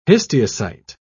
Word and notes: histiocyte: British English

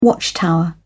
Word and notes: watchtower: British English

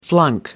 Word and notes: flunk: American English

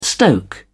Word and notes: stoke: British English

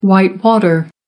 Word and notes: whitewater: American English

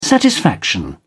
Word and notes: satisfaction: British English

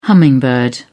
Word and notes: hummingbird: British English

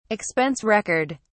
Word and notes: expense record: British English

